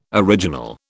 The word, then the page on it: original: British English